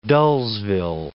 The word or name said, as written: dullsville